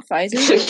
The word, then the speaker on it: Pfizer: Pfizer said this last month. – Do you mean Pfizer? Yeah. Because I think the P is silent.